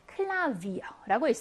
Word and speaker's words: Klavier: 파오가 단어 중간에 있는 모와 모 사이, 모음과 모음 사이에 들어가 있어요. 그럼 무슨 발음? V 발음이에요. 클라비어라고 있습니다.